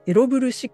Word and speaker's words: ELOVL6: エロブル 6 って読むんですかね。はい、 ELOVL6 ですね。はい。で、これがすごく鍵になっているっていうことなんですけれども、どういうことなんでしょうと、このエロブル 6